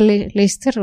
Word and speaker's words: Leicester: Se, se escribe Leicester. En Inglaterra se pronuncia Leicester o algo así.